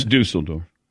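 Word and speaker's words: Düsseldorf: Hansi Nod, if you're pronouncing it right, later turned onto coaching in Dusseldorf and had several stages with the German Basketball Federation. – It's Dusseldorf. – What I call the Dusseldorf? – Yeah. – Dusseldorf.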